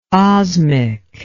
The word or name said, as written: osmic